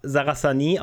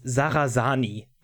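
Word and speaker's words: Sarrasani: okay, aber auf alle Fälle, ähm, unser, unser äh, lieber Giuseppe Giovanni Sarasani, ähm, äh, äh, übrigens, äh, ich, ich habe den mehr, mehrfach Sarasani ausgesprochen oder so, weil das halt irgendwie normaler klingt und alle Sachsen sagen, nein, nein, der ist Sarasani wie Sani am Ende und ich muss immer wieder lachen.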